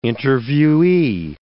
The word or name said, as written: interviewee